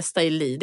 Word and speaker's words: Styly: Uttalar jag Styly rätt? – Ja, vi brukar säga Styly. Det kommer liksom från så här trainer, trainee, stylist, Styly.